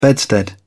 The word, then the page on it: bedstead: British English